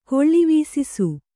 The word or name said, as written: ಕೊಳ್ಳಿವೀಸಿಸು